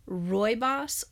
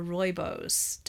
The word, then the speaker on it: rooibos: So anyway, one of the health blogs, Facebook people that I follow, recommended trying, and I just Googled how to say this, rooibos or rooibos, depending on, you know, whoever you are, tea.